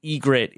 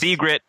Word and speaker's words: Ygritte: And thank you, Matthew, for now explaining to me that the Y in Ygritte is silent. Silent Y at the start of a name.